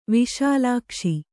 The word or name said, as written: ವಿಶಾಲಾಕ್ಷ